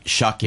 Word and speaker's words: Shuck: We're doing a little promotion ourselves that's going to be called Shuck in. – No, don't go down that road. – It rhymes with duck.